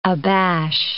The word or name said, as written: abash